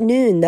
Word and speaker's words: Nuun: I also like Noon. Those are those tablets. It's spelled N-U-U-N, and they have hydration electrolyte tablets.